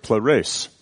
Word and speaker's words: pleres: The word full in the Greek that's used here is the word pleres, as we would pronounce it.